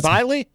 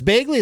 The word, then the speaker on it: Bagley: Is it Biley? – That's Bagley. – Oh, it's Bagley? Is that how you say it? – Yeah, it's Bagley. – The GH combination made me think it was silent.